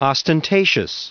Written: ostentatious

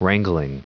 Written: wrangling

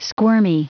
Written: squirmy

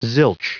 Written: zilch

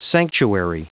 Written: sanctuary

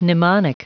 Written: mnemonic

